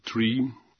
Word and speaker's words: Tree: Empfehlungen wie 9, das wird Niner ausgesprochen, 4, Fourer und 3, eher wie Tree.